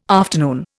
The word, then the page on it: afternoon: Indian